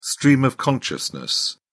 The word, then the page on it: stream of consciousness: British English